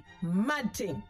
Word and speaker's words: mad ting: Two words. Mad ting. M-A-D T-I-N-G. Mad ting. This is how it's said.